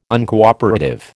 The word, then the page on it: Uncooperative: American English